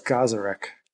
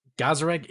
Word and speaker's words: Gazarek: Sarah Jazarek, right? – I always thought it was Gazarek. – Gazarek, you're most likely right.